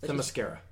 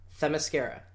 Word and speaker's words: Themyscira: How do you pronounce the island? – Themyscira. – Themyscira. So like, Themyscira. Themyscira. – I think. – You think. It's like I'm saying the mascara.